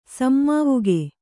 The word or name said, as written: ಸಮ್ಮಾವುಗೆ